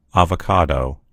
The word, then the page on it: avocado: American English